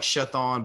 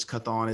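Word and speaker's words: Chthon: Looks like Shathon, but it's Cthon.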